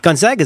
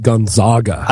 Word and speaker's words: Gonzaga: Especially since a lot of people mistakenly call it Gonzaga. – I called it Gonzaga until I heard your dad pronounce it in front of me once. And until then, I thought it was Gonzaga.